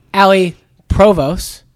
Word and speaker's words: Ali Provos: Tech recruiter, Allie Provos.